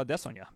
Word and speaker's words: Adesanya: So if that's and there's is and that's why do I say Israel Adesanya because that's how Israel says it's pronounced.